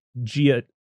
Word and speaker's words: Giacchino: Music was done by Michael Giacchino, which I figured out that's how you pronounce his last name because I watched,